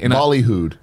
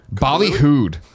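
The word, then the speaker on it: ballyhooed: The Epic Game Store's much beluied. Behooied? How do you say it? Beluied? Beluied, right? It's a weird one. I've seen it written out, but I've never said it. – Bollyhooed. – Bollyhooed.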